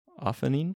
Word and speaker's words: Ophannin: I always call that the ophanin. – Ophanin. Sure. – It's O-P-H-A-N-N-I-N.